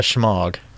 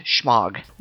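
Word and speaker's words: shemagh: No, but it's pronounced pretty close to the same. It's an Arabic word. If you want to get really technical, I suppose it would be pronounced schmog, but Americans have blurred it, and now it's just pronounced schmog.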